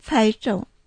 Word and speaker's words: 猜中: 当たったっていうのは、猜中って言います。この発音は難しいですか？猜中。 – そうですね。猜、猜の部分がね。